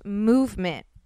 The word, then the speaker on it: MVMT: So let me explain, because it's MVMT watches, but it's pronounced movement watches.